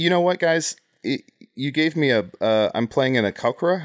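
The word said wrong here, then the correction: Aarakocra: How do you say that? – Arakokra. – Oh, that's Arakokra.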